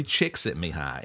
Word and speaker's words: Csikszentmihalyi: Mihaly Csikszentmihalyi. And phonetically, his last name sounds like Chick, Scent, me high.